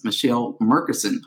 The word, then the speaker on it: Mechelle Merkerson: I'm your host, and today we have with us Mechelle Merkerson.